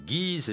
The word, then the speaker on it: Guise: Et dans ce pays, une ville à la campagne qui s'écrit Guise et se prononce Guise.